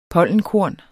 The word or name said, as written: pollenkorn